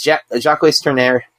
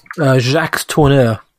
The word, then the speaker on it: Jacques Tourneur: It's Jacques Tourneur. I believe I'm saying that wrong. – Jacques Tourneur. – Yeah, there you go.